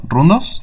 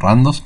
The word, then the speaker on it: RunDos: Eh, son, por ejemplo, primero Rundos, o Randos, como lo estás pronunciando vos.